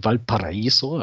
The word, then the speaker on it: Valparaíso: Ich selber lebe in Valparaíso, das ist völlig korrekt ausgesprochen, perfekt.